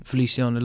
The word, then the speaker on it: Feliciano: Is it Feliciano they pronounce his first name? Feliciano Lopez? – Yeah.